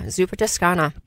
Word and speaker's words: Zuppa Toscana: The one that caught my eye was the Zuppa Toscana. Am I pronouncing that correct? – Yeah, Zuppa Toscana.